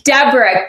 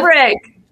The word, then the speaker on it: Debrucque: Debrucque. But that is Debrucque. That is the common way to pronounce it if you're not sure, is Debrucque.